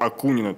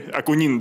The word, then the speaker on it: Акунин: вы пытались создать своего Акунина, то есть с японского означает выдающуюся личность. Акунин, да. Акунин. Правильное ударение поставил.